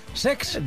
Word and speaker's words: Cesc: El Como de Cesc Fàbregas, que se estrenó... – Lo he dicho mal, es Sex.